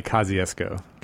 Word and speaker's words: Kosciuszko: In Mississippi, we say Kosciuszko. – Kosciuszko. – I'm not sure how they say it here, which I'm sure they would retain the Polish pronunciation.